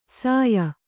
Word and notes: ಸಾಯ: sāya

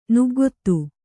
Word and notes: ನಗ್ಗೆತ್ತು: naggettu

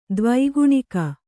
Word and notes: ದೇವಗಣಿಕೆ: Kannada

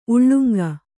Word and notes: ಉಲ್ಲಂಗಿ: kannada

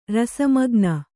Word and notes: ರಸಮಗ್ನೆ: rasa magne

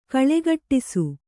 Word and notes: ಗೋಳುಗುಟ್ಟಿಸು: gōḷuguṭṭisu

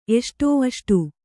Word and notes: ಎಸಡು: Kannada

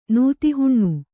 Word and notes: ನೀತಿಹೀನ: nīti hīna

